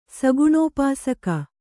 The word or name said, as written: ಸಗುಣೋಪಾಸಕ